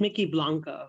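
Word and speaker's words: Mykki Blanco: And I, please correct me if I'm mispronouncing this, but with this, Mickey Blanco, is that right? – It's Mickey Blanco.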